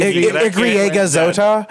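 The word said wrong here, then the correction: i griega zeta: Igriega Zeta 50.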